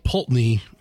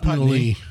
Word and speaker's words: Pulteney: First off, Old Pulteney. Putney. – That's pronounced Putney. Spelled Pulteney.